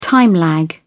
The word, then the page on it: time lag: British English